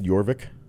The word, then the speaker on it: Jorvik: Jorvik? – Yeah. Silent J.